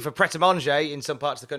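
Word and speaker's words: Pret A Manger: Right, I'm not sure if I'm pronouncing this right, but Pret-a-Manger. Pret-a-Manger.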